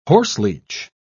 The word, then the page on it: horseleech: British English